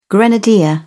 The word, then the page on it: grenadier: British English